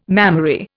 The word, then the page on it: mammary: Indian